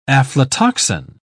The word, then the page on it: aflatoxin: English; British English